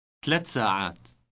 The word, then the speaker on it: ثلاث ساعات: In Arabic, however, the e and a endings are dropped when numbers are used before nouns. Listen to the word for 3. – ثلاثة ثلاثة. – Here's how it is pronounced with the word for hours.